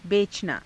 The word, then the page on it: becanaa: hindi